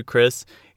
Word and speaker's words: Chris: One of the reasons I am obsessed with the song is because in the rest of the show, when you're talking to Chris, you say her name with one syllable. But in this song, it's almost impossible to sing it without breaking the name into Chris. Two syllables.